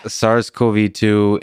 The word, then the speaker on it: SARS-CoV-2: SARS-CoV-2 indicates evolutionary conserved functional regions of viral proteins.